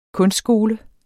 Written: kunstskole

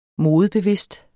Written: modebevidst

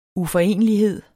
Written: uforenelighed

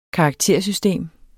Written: karaktersystem

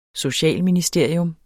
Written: socialministeriet

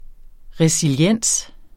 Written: resiliens

